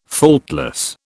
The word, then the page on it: faultless: British English